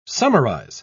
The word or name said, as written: summarize